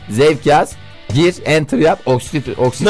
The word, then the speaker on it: Oksitosin: Bu oksitokin diye bir şey var ya. – Oksitosin evet. – Oksitosin mi? Hı. Ha doğru, Almanca olunca S okunuyor. Oksitosin.